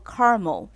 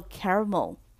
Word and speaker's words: caramel: 那焦糖这个字呢，叫做 caramel，另外一种发音叫做 caramel，都可以。